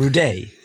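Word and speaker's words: Rudé: But when he went to school, he realized the best thing he could do was add an accent on the E and give it a kind of French sound, Rudet.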